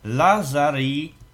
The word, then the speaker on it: Lazarillo: Lazarillo. – Czyli dlaczego? Po prostu Lazarillo. – Tak. Dlaczego? Dlatego, że te ostatnie dwie L-ki wymawia się jako I. I wtedy wychodzi z tego Lazarillo.